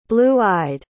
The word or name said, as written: blue-eyed